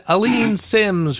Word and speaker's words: Aleen Simms: And Aleen Simms, whose name I may have mispronounced. – Actually, you got it right.